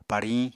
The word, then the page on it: pariñ: Apatani